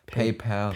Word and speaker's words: PayPal: Spricht man das echt PayPal aus? – Ja, ich wie Pal. PayPal. PayPal. PayPal. PayPal. Sagt okay. man doch, weil jeder ja, sagt PayPal. PayPal, PayPal müsste es ne?